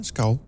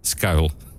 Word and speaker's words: Scowl: Hoe zou je die bandnaam uitspreken? – Ja, Scowl. – Jawel, hè? – Ja, zeker. – S-C en een owl. Als in de vogel, uil. – Scowl. – Ja, Scowl. Op zijn Nederlands.